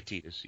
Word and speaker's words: Tidus: Is it really pronounced Tidus? – I always say Tidus, yeah. Okay. Yeah. I believe the Japanese pronunciation is Tidus. – Okay. – So, that's just what feels right for me to say.